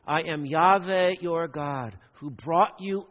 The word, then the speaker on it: Yahweh: Remember, this word, Lord, in all caps in your Bibles, is the Hebrew name for God, perhaps pronounced something like Yahweh.